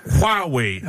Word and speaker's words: Huawei: Huawei, okay. Ja. Der vil bare sige Huawei.